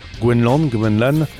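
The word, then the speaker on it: Gwenc'hlan: Alors attention, ça pourrait se prononcer différemment parce qu'il y a un C-H apostrophe. Gwenlan.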